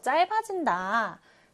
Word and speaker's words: 짧아진다: 짧아진다. 짧아진다. 짧아진다. 짧아진다라고 할 때는 받침 리을만 남고 짤. 그 다음 이응 자리에는 비읍이 가서 짧아진다. 이렇게 읽어줘야 합니다.